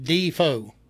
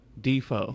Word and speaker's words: DeFoe: Well, hey, they pronounce it Defoe. – Defoe.